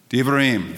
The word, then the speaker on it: Devarim: How do you pronounce that? Devarim, very good. So that's how you would pronounce it, which basically means words.